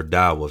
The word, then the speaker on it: Tao: But there's this book called The Tao of Sex, or Tao of Sex, really, because the T is pronounced as a D.